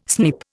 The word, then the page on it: snip: Indian